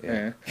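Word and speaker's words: Geschichte: 그 독일어로 그 역사라는 게시이테라는 말이 있는데 그거를 거의 정립한 사람이 이 사람이에요. – 게시이테? – 네. – 어, 이름 어감 쉽지 않은데? – 네, 좋은데요? – 독일어 역사를 게시이테라고 해요. – 게시이테?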